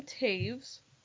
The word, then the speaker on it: Toews: Her name is Miriam Toews, and that's spelled T-O-E-W-S, which is a Mennonite name. – T-O-E-W-S. T-O-E-W-S. – Miriam Toews is how you pronounce it.